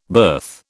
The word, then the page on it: berth: British English